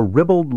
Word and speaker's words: ribald: The word should rhyme with scribbled and dribbled. And speaking of rhyme, for your verbal advantage, edification, and delight, I have composed a ribald limerick to help you remember the proper pronunciation of the word.